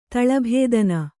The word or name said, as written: ತಳಭೇದನ